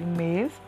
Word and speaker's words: mes: Então se escreve mes, tes, ses. Só que não pronuncia, tá? Só pronuncia me, te e se.